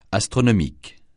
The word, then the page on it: astronomique: francais